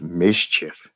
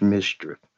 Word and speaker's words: MSCHF: Now, this company, MSCHF, which is pronounced mischief, MSCHF, pronounced mischief, mischief is from Brooklyn, New York, and they also being sold over the Jesus sneakers.